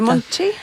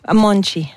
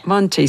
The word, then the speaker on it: Monte: On dit Monte? – Monchi. – Monchi, ça s'écrit M-O-N-T-E pour les intéressés.